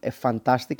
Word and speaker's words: FNTASTIC: η Fantastic, αν το προφέρω σωστά γιατί γράφεται FNTASTIC, οπότε λείπει ένα U.